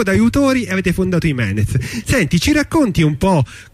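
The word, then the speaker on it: Jiménez: però è scritto J-E e quindi Jimenez diventa Jimenez, Jimenez, e c'è chi rievoca lo scrittore spagnolo, chi altri personaggi esistenti.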